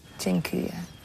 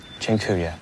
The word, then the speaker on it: Dziękuję: Dziękuję. – Dziękuję.